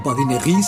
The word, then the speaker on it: Badinerie: Badinerie se escribe badinerie y solemos pronunciar en, bueno, castellanizado, badinerie en español. Con B, que con V sería otra cosa.